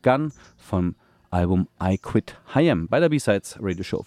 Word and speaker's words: Haim: Die nennen sich H.I.M., also wird H.I.M. ausgesprochen, aber Heim geschrieben, H-A-I-M.